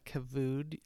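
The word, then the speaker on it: kavod (כבוד): I had Googled you before this little sit down, and that word, you did that in a, used that kavod, is that how you pronounce it? – Uh-huh.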